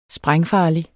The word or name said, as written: sprængfarlig